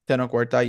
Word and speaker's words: Tenoch Huerta: Tenoch Huerta, yes.